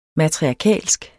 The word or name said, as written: matriarkalsk